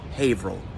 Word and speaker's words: Haverhill: You wouldn't have guessed it, but it's Haverhill.